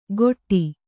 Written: ಖೊಟ್ಟಿ

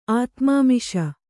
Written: ಆತ್ಮಾಮಿಷ

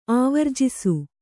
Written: ಆವರ್ಜಿಸು

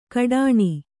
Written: ಕಡಾಣಿ

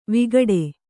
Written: ವಿಕಟ

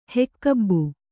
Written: ಹೆಕ್ಕಬ್ಬು

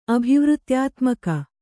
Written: ಅಭಿವೃತ್ಯಾತ್ಮಕ

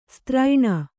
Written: ಸ್ತ್ರೈಣ

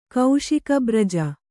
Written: ಕೌಶಿಕಬ್ರಜ

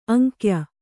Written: ಅಂಕ್ಯ

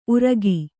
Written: ಉರಗ

